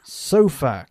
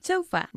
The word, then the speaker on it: sofa: Sofa. Cambiando el énfasis.